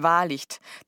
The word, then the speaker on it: Wahlicht: Der Nachname ist Wahrlicht. Das schreibt man W-A-H-L-I-C-H-T.